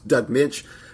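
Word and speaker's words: Moench: I believe that's how you pronounce the man's name. It's always mangled by us podcasters, but I do believe it's Doug Minch.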